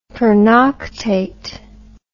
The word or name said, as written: pernoctate